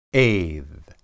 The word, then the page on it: ed: hebrew